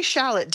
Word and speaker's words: shallot: And now how do you pronounce S-H-A-L-L-O-T? – Oh, I say shallot.